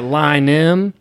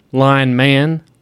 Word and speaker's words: Lynam: It's spelled just like it sounds. – Yeah. – L-Y-N-A-M.